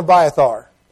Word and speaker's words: Abiathar: All right, Zadok and Abiathar.